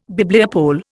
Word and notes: Bibliopole: Indian

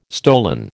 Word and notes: stolen: American English

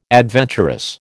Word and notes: adventurous: American English

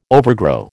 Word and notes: overgrow: American English

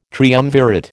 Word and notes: triumvirate: American English

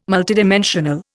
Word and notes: multidimensional: Indian

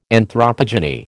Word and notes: anthropogeny: American English

